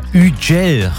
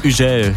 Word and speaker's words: Guetta: Ja, Ugel heißt der, wie ich letztens gelernt habe. Französischer DJ, oder wie würdest du ihn aussprechen? – Ja, okay. Ugel, ja. – Ugel.